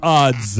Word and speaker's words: Odds: 2e position, Sufjan Stevens avec The Age of Ads. Ads. – Ah, faudrait expliquer à chaque fois qu'on dit, parce que c'est Sufjan Stevens avec The Age of Odds qu'il faut prononcer.